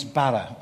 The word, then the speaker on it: bara: And it's the word create, and it means bara. I can't say it because you have to roll your R's. I can't roll my R's. So imagine like a Welsh bara, deep tones.